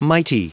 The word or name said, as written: mighty